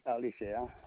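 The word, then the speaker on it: Alicea: Jose Alicia.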